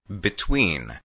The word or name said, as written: between